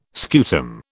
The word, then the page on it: scutum: British English